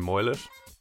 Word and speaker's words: Moylett: Kind of similar to this as well, I grew up in Ireland, as you can probably tell, and I pronounce my name Moylett, but when I moved to the States in 2016 to coach and play with Lindenwood University, literally nobody could understand me when I was telling them my name, like nobody. They were all like, Moylan, Moylam, Moylan, and then I would spell it out and they'd say, ah, Moylett.